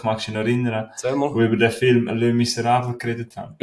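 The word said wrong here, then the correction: Les Misérables: Vom 2000, haben sie jetzt schön ausgesprochen? Le Miserables. – Le Miserables. Les Miserables. – Genau wie der Milo gesagt hat, so heisst der Titel.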